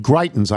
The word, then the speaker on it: Greitens: Greitens, I believe, am I pronouncing that right? – Yep.